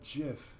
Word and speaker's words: GIF: And during his speech, he's like, I know everyone calls it a GIF, but I'm telling you, it's called GIF. Like, he's like... – It's a soft G.